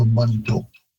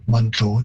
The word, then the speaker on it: Manitou: Again, the man in the pronunciation is due to English looking at that letter A, and I wanted to use an ah sound, but it would be more like a short ah in Cree, so Manitou. And again, also Manitou, all three syllables are being placed in the English pronunciation, whereas in Cree it would be very common to sort of reduce that middle syllable right out of there, and Manitou, it sounds more like a two-syllable word.